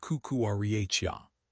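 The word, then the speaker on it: Marc Cucurella: along with defenders like Pedro Porro and Mark Cucurella, pronounced Cucurella.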